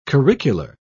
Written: curricular

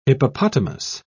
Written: hippopotamus

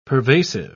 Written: pervasive

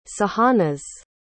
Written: sahanaz

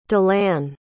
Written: dilanne

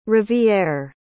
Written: rivière

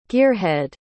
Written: gearhead

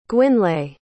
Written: gwynlais

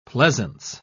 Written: pleasance

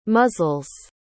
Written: muzzles

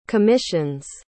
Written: commissions

